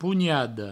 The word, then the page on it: ponhada: pougnado; provençal